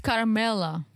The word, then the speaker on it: Carmella: We see an art card come up on screen and it says, during production, the producers uncovered some information about Tyrae's girlfriend, Camella, that he was unaware of. – It's Carmella. – Carmella. What was I saying? – Camella. – Silent R.